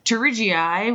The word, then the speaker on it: pterygii: And then the pterygii, which has a silent P like pterodactyl,